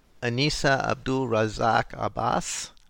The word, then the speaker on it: Anisa Abdul-Razzaq Abbas: Your mother, Anissa Abdul Razak Abbas, pronounced it.